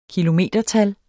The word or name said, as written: kilometertal